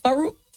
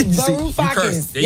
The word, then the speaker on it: Varoufakis: Varoufakis.